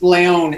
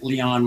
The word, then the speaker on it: Leon: Yeah, well, the first Leon is the proper way, but I've heard it Leon my entire life, so it's all good.